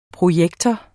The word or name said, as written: projektor